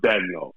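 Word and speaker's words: Daigneault: Is it Dagnault or Dagnault? Like, what is the correct pronunciation? – I'm going to go with Dagnault. Okay. That's what it is.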